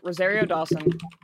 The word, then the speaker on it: Rosario Dawson: Rosario Dawson. – Did you say it right? – Is that how you say it? Okay. I feel less bad now. Yeah. Owen Wilson. – Rosario Dawson is correct.